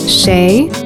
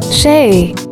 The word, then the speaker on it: شاي: Shay. – Tea. – Shay.